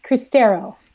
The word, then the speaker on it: Cristero: Cristero.